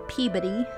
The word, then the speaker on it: Peabody: Also in Salem, the Peabody Essex Museum houses the original court documents, or Peabody, how they say it in Massachusetts,